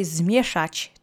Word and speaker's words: zmieszać: Now let's say zmieszać together three times. Accent is on the first syllable.